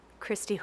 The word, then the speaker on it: Kristi: Christy Hushka. – Christy is spelled? – The last, Christy is K-R-I-S-T-I. – And your last name, please? – Hushka is H-O-U-S-K-A.